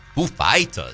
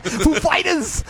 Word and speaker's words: Foo Fighters: You also said the name of the band wrong. – What? – Foo Fighters. – Oh, sorry. Foo Fighters.